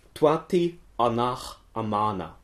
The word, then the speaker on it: Tutanchamun: Ja, und wie klingt dann so ein Name wie Tutankhamun, wenn man das versucht, sich dem Original anzunähern? – Tuati Anach Amana oder sowas in der Richtung.